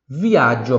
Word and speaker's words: viaggio: Se escreve V-I-A-G-G-I-O, tá? Ó, vou até, nessa palavra aqui, vou botar um D para você visualizar bem a pronúncia dessa aqui, para quem está vendo no YouTube. Viaggio, tá vendo ali? Viaggio, viaggio, tá? Viaggio. Como se tivesse som de... Como se tivesse, como se tivesse o D ali antes do G.